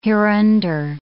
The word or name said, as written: hereunder